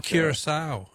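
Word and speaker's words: Curaçao: And I don't know if I pronounced it right, but I thought it was Curacao. – Oh, Curacao. Yeah. No, that's very good. I'm sure you're pronouncing it correctly, but that's not Dutch West Indies.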